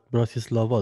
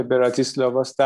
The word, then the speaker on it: براتیسلاوا: گفت نزدیک به وین، پایتخت اسلواکی براتیسلاوا، درسته؟ تلفظش؟ – بله براتیسلاواست. در واقع آره.